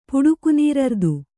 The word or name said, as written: ಪುಡುಕುನೀರರ್ದು